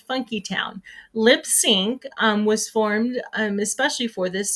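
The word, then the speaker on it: Lipps Inc.: All right, we're going to move on while I look that up to Funky Town by Lips Inc. And it's, and I never knew this. I keep saying Lips Inc. and it's actually supposed to be Lip Sync. That's how you're supposed to pronounce it.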